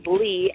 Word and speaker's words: Lee: My Twitter at symbol is Kenesha, and it's spelled a little different, K-E-N-E-T-I-A. My last name is Lee, L-E-E.